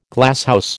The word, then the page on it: Glasshouse: American English